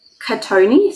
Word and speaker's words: Cattoni: Alex Cotone, so C-A-T-T-O-N-I.